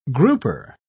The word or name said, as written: grouper